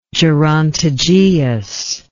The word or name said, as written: gerontogeous